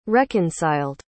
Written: reconciled

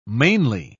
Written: mainly

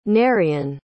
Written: Nerian

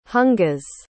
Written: hungas

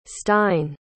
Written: stine

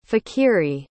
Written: fakhiri

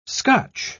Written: scotch

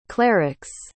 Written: clerics

